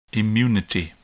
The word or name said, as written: immunity